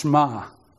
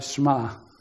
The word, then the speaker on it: Shema: It's actually pronounced Shema. Okay? So, Shema.